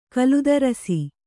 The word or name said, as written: ಕಲುದರಸಿ